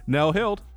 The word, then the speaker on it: Nell Heald: Nell Hild.